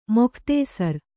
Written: ಮುಕ್ತಾಸಾರ

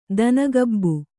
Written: ದನಗಬ್ಬು

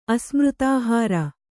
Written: ಅಸ್ಮೃತಾಹಾರ

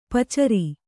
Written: ಪಚ್ಚೇರಿ

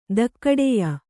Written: ಧಕ್ಕಡೆಯ

